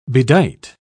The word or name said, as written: bedight